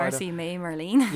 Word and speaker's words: Martha Marcy May Marlene: Marcy, May, Marlene.